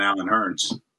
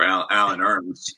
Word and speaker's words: Allen Hurns: Might be time to re-sign Allen Hurns or Allen Hurns. – Allen Hurns. – Depending on how you want to pronounce it.